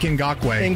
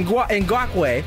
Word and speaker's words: Ngakoue: With Tony Quinn Jr. How do you say the defensive end? – Unique Ngakoue. – Ngakoue.